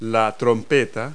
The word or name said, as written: trumpet